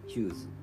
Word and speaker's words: fuse: FUSE と書いてヒューズという単語なんですがローマ字読みをしますと「伏せ」となります。